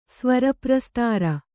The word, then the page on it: ಸ್ವರಪ್ರಸ್ತಾರ: svara prastāra